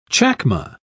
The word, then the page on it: chacma: British English